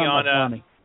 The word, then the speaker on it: Maslany: Tatiana Maslany.